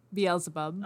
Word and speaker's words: Beelzebub: Is that how you pronounce it? – Beelzebub. – Oh, yeah. Okay, because I was like, is that in Bohemian Rhapsody? That's how they pronounce it, but I thought they were just, like, pronouncing it weird.